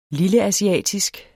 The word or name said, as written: lilleasiatisk